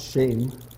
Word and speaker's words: shem: The Hebrew word for name, spelled S-H-E-M, it's pronounced shame, but it means renown, implying one to whom all honor and authority belongs, right?